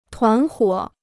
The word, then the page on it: 团伙: tuán huǒ